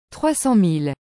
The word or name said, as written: 300000